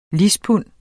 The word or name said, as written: lispund